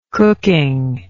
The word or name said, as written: cooking